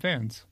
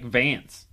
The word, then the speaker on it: Vance: He just says it like Vance. You say it like Vans, like the shoes. – Is it Vance? – Yeah, Bob Vance, Fans Refrigeration.